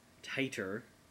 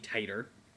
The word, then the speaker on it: titre: I would pronounce it a Mantoux test, but I would also pronounce titre, so like a hepatitis B titre, but no, it's pronounced hepatitis B titer.